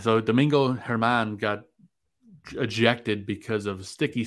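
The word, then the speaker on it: Domingo Germán: Domingo. No, was it German? Yeah. – Oh, Domingo Herman.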